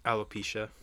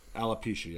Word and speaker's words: alopecia: Jane 2 because she's bald because she has apalgia. I don't know how you pronounce it. – Alopecia. – Alopecia, yes. And next thing you know, Will Smith walks on stage and slaps the shit out of Chris Rock.